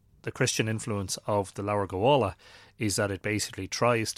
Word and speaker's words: Leabhar Gabála: But the invasion myths belong to a book called Leabhar Gabála, or Leabhar Gabála, as it would be pronounced in modern Irish, and that is the Book of the Takings of Ireland, or the Book of Invasions, as it's commonly known.